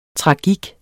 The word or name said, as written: tragik